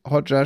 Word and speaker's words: Hotjar: Hotjar spricht man, das war ein J, aber das ist Jar heißt auf Englisch Glas, ne? Marmeladenglas.